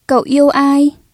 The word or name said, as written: cậu